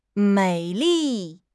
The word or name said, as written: mei3 li4